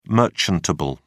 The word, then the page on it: merchantable: British English